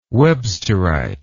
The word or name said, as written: websterite